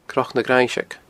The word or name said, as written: cnocnagrainnseig